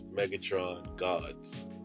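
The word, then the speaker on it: Megatron Godz: So my Activision name is Megatron, but my Xbox Live gamer tag is Megatron Gods. GODZ.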